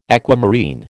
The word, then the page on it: aquamarine: American English